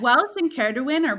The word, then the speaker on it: Ceridwen: Ceridwen, yeah, that's how you say it, I think. – Oh, yeah, that's actually like, Welsh, yeah.